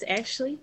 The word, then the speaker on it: Ashley: Hi, it's Ashley, no E.